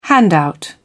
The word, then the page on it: handout: British English